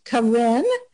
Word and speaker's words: Karin: It's pronounced Karin, and it's spelled K-A-R-I-N. That's the first name. Last name is easy. It's Bursa, B-U-R-S-A.